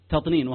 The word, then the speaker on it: تطنين: التطنين تا طا نون يا نون تطنين